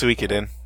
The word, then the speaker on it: Suikoden: I think it's pronounced Suikoden, if we're being proper.